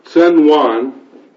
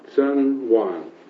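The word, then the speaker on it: Tsun Wan: Sun Wan, I suppose that's the way you pronounce it, two words, capital T-S-U-N, capital W-A-N, and you want to put that down for prayer. Sun Wan, capital T-S-U-N, capital W-A-N.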